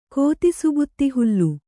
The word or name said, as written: ಕೋತಿಸುಬುತ್ತಿಹುಲ್ಲು